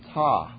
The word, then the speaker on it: Ptah: One of the three major gods of Egypt was a god named Ptah. And that's with a P in front of it. It's one of those silent P's.